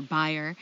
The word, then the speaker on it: Bayer: Bayer Crop Science, or Bayer, as it's pronounced.